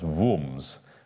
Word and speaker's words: Worms: Now, if any of you know German, that's pronounced Wurms, but I just think Diet of Worms.